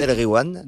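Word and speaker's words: Nas El Gwiam (ناس الغيوان): Nas El Gwiam. – Nas El Gwiam, voilà. – Et quand il y a GH, tu dis re. Tu fais comme si c'était un R.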